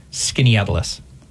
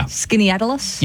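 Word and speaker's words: Skaneateles: You're gonna hate that this is how it's pronounced, Sarah. It's very unfair for me to challenge you with that name. It's actually pronounced Skinnyatlas. – Is it? – Yeah. – Skinnyatlas? – Yeah, or just Skinnyatlas if you want it. Yep. Skinnyatlas. You can just say it that way.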